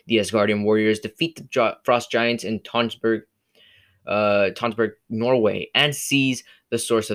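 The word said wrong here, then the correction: Tønsberg: I totally mispronounced Tonsberg. I'm not supposed to pronounce the R. Tonsberg, Norway, and seize source of their power, the Casket of Ancient Winters.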